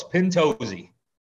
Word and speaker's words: Pintozzi: And Joey Pintozzi, did I say that right? – Very close. Pintozzi.